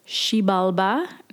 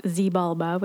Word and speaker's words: Xibalba: So based on the pronunciation guide I found on the internet, it's Xibalba, not Xibalba, which is how I was initially saying it, but X's are pronounced with a sh sound.